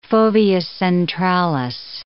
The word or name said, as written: fovea centralis